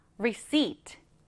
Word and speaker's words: receipt: 얘는 발음하실 때 receipt, P가 묵음이에요. 없는 것처럼 발음합니다. receipt, receipt.